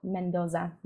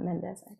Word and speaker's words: Mendoza: And also, it's Sergio Mendoza, not Mendez.